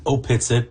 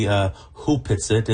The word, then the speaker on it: Hupitsit: It's written Opitsit, but it's Opitsit. – Actually pronounced properly Hupitsit.